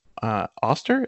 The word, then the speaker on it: Auster: But Andy recommended Oracle Night by Paul Auster. Is that how you pronounce his name? – Yeah, yep.